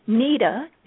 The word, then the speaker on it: Nita: Well, Nita is N-I-T-A.